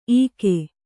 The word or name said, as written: ಇಕೊ